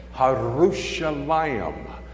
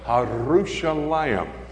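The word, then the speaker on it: Harushalayim (ירושלים): The word in Hebrew is pronounced like this, Harushalayim. You got to twirl your tongue. Harushalayim.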